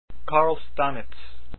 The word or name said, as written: Stamic